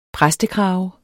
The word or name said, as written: præstekrave